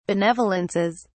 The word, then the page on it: benevolences: British English